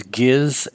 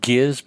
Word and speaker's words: Giz: We find out that this is Giz and Dave, and I will pronounce it Giz because it's a G-I-Z. Hard G because soft G just sounds very yucky here, so I'll go with Giz.